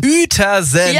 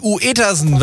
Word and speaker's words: Uetersen: Es gibt einen Ort in Schleswig-Holstein, der spricht sich vorne mit Ü, schreibt sich aber nicht mit Ü. – Na, welcher kann das sein? – Uetersen. Ja. Eigentlich Uetersen, wenn man es mal so liest.